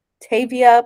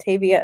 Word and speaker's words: Tavia: My username is at Tavia Plays the Music. And Tavia is spelled T-A-V-I-A Plays the Music.